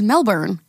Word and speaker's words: Melbourne: Yeah, you know it's Melbourne? Yeah. I thought it was Melbourne my whole life. It's Melbourne. Like burn.